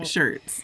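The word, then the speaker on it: Schertz: I mean, it looks like Shirts, Texas. Shirts.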